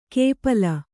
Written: ಕೇಪಲ

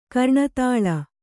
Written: ಕರ್ಣತಾಳ

